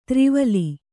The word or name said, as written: ತ್ರಿವಲಿ